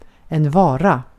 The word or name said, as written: vara